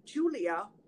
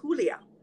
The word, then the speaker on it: Julia: And for anybody uh, at home who is not familiar with the Spanish language, it's, well, we would say Julia, but the Spanish pronunciation is, of course, Julia.